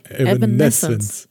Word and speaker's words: Evanescence: Du musst das Essence hinten betonen. Evanescence.